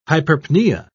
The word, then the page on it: hyperpnea: British English